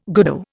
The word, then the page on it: goodo: Indian